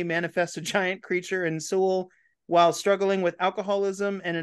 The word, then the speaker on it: Seoul: How do you pronounce the city? S-E-O-U-L? In Seoul? – Yeah, Seoul in Korea. – Seoul, Korea.